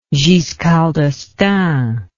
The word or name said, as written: Giscard d'Estaing